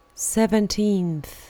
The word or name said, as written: seventeenth